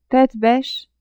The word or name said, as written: tete-beche